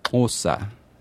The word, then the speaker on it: Xhosa: I'm not going to pronounce it right, but the language it's spelled X-H-O-S-A is Osa.